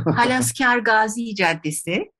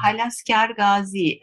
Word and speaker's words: Halaskar Gazi Caddesi: Telaffuzu hayli zor bir caddemiz var. İnşallah ben doğru telaffuz edeceğim. Halaskar Gazi Caddesi. – Doğru oldu mu? – Evet, bence oldu.